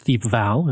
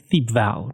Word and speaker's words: Thiepval: Now, we're going to be talking about the Battle of Thiepval Ridge, and I've heard it pronounced Thiepval and Thiepval. I'm going to say Thiepval, because that just works the best for me with my Albertan accent, and I hope it works for you guys too.